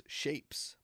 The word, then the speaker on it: Shvpes: All right, anyway, my next one is Shapes, but it's spelled SHVPES.